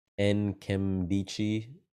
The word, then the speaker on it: Nkemdiche: Nkemdiche, sorry.